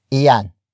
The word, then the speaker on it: iyan: Yang is spelled as apostrophe Y-A-N-G. It is short for iyan. Iyan. Iyan. Which is spelled as I-Y-A-N.